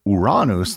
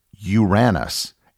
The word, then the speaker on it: Uranus: Early English dictionaries had the word pronounced as Uranus, as in you ran us over with your car.